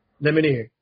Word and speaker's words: Lemonier: Is it Lemonier or Lemonier? How did he say his name? – Lemonier.